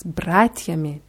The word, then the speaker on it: братьями: Z bratiami. Die Betonung ist auf A.